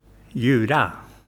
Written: Ljura

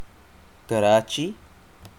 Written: Karachi

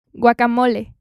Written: guacamole

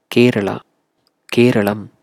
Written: Kerala; Keralam

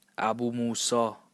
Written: Abu Musa

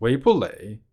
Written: 维普蕾

